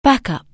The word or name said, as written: backup